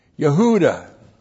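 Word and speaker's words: Yehuda (יהודה): Y, Shiva, H, long U, D, A, H. Yehuda.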